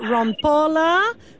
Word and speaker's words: Rampola: I'm talking Alessandra Rompola. Good morning, Alessandra. – Hi, good morning. You pronounce my name beautifully.